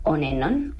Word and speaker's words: Onenon: Bueno, se escribe Onenon, o sea, O-N-E-N-O-N. Entonces, es un juego fonético de la frase en inglés On Anon, que si pues lo traducen es una y otra vez.